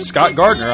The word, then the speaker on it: Scott Gardner: And hey, you can friend me, Scott Gardner, on Facebook too. My name is spelled S-C-O-T-T-G-A-R-D-N-E-R.